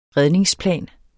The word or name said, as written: redningsplan